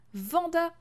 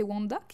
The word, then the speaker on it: Wanda: mais il s'agit de façon très évidente de Vanda Maximoff, Wanda Maximoff, peu importe comment on le prononce, mais à l'anglais c'est Wanda qui disent.